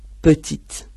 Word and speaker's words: petit: Por ejemplo, petit. En petit, pequeña, la primera E, pues está ahí suelta, no lleva ningún acento, está en final de sílaba, se pronuncia E.